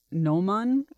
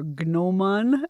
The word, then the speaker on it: Gnomon: At the urging of Ron Bailey, I am reading Nick Harkaway's Gnomon, or Gnomon, as it is spelled.